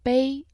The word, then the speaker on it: 杯: Cup in Chinese is bei, right? – Yes, bei, the first tone.